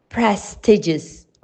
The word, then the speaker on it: prestigious: Two issues may cause people to mispronounce the word prestigious. First, the noun form, prestige, has a long E noise, prestige. So they assume that the adjective does too. Second, The word appears to have four syllables, not three. So that's why we pronounce correctly prestigious three.